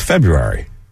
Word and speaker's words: February: It's February. – Right, and it's two syllables, and he's calling sports talk radio, so he's a fan.